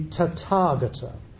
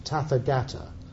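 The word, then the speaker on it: Tathāgata: He's often, when he speaks about himself, he uses this curious expression, the Tathagata, or you might pronounce it Tathagata, T-A-T-H-A-G-A-T-A, the Tathagata.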